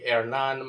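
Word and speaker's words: Hernán: or if you kind of pronounce it, the H in Spanish is silent, so it would be Hernan Mateos of Nicaragua, approached the woman while she walked across a gas station parking lot.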